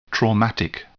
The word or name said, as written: traumatic